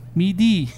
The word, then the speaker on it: MIDI: Ano ba yung MIDI keyboard na yun? – MIDI. – Is that how you pronounce it? MIDI, yeah.